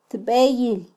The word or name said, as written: tbeyil